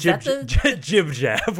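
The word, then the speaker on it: JibJab: Gib-Gab? Was Jib-Jab. We're not getting into the hard, soft G terrain oh, okay.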